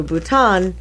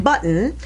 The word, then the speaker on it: Bhutan: And laughingly referred to Bhutan, if I'm saying that correctly, I'm hoping, as button, according to two sources with knowledge of the meeting.